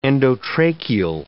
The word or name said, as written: endotracheal